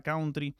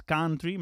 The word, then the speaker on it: country: Retomamos una cuestión con Félix Moreno Cabo, un oyente que nos escribió para, bueno, decirnos que la pronunciación de la palabra country no era así como tal, sino que era más country, más abierta con la A.